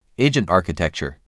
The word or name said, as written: Agent architecture